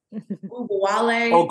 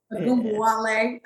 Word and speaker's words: Ogunbowale: Ogunbowale. – Ogunbowale.